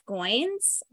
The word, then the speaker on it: Goins: was called Real Artists Don't Starve by Jeff Goins, rhymes with coins.